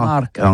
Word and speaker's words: marcat: Eh bien, d'ici à toutis, le mercat de l'Uni ou le mercat des rebelles, mais en gascogne toulousano, c'est prononcé sur les marcat, avec un A, marcat.